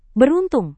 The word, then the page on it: beruntung: Indonesian